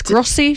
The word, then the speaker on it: Grossey: It's Yes, it's Grossey to rhyme with bossy, and it's GROSS for sugar, EY.